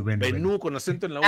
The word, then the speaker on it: Benú: Benú, con acento en la U.